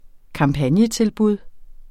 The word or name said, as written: kampagnetilbud